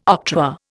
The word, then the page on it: octroi: Indian